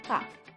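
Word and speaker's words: ha (ห้า): In Thai language, 5 is pronounced as ha, which when translated 555 becomes ha ha ha.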